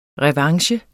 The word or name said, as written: revanche